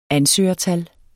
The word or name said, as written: ansøgertal